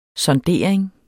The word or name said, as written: sondering